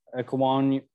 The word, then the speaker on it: Ekwonu: Ikem Ekwonu. – Ekwonu.